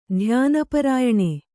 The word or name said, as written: ಧ್ಯಾನಪರಾಯಣೆ